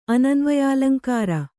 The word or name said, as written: ಅನನ್ವಯಾಲಂಕಾರ